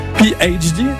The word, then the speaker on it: PhD: 7h20, voici PhD, c'est comme ça que l'on prononce,